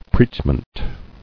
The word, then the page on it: Preachment: preach·ment